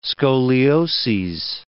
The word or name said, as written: scolioses